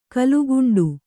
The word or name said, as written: ಕಲುಗುಂಡು